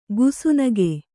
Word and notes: ಗುಸುನಗೆ: kannada; gusunage

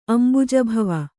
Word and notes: ಅಂಬುಜಭವ: kannada

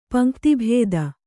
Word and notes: ಪಂಕ್ತಿಭೇದ: paŋkti bhēda

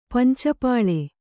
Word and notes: ಪಂಚಪಾಳೆ: panca pāḷe

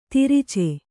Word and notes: ತಿರಿಚೆ: tirice

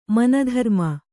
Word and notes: ಮನಧರ್ಮ: mana dharma

